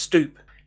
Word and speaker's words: stoep: Stoep, which is pronounced stoop. It's from the Dutch, and it was the Dutch settlers in New York that coined it.